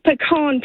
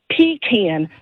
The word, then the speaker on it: pecan: It's pecan pie, not pecan. A pecan is a porta potty.